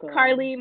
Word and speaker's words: Carly: Yeah, my name is Carly. My Instagram is it's Carly B with two Y's. Carly is spelled like C-A-R-L-Y.